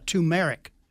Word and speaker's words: turmeric: Curcumin, C-U-R-C-U-M-I-N. And the caller also mentioned turmeric, T-U-R-M-E-R-I-C, turmeric. Okay? Turmeric. There you go. I had the emphasis on the wrong syllable.